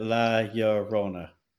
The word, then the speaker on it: La Llorona: La Llorona, pronounce Llorona. Yep, sailors got it. La Llorona.